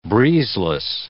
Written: breezeless

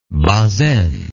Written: Bazaine

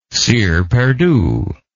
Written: cire perdue

